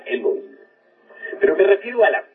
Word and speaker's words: Elohim: Ahora, Elohim es una palabra que se escribe con H, Elohim, que se pronuncia Elohim, porque la H suena como J, y significa diosas y dioses.